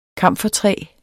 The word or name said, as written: kamfertræ